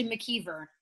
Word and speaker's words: McIver: Yeah, it's actually McKeever. – But oh, McKeever. Okay, not like the TV show.